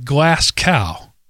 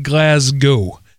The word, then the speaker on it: Glasgow: I should warn you that Glasgow does not rhyme with glass cow. No, it's more like Glasgow.